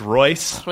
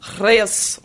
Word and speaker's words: Reus: Probably Reus. – Reus. – Or if it's German, it's Royce. – Reus. – Reus. Let's go with Reus. R-E-U-S.